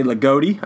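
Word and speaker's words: Ligotti: I say Ligoti. I said it right? – Yep. – Ligoti.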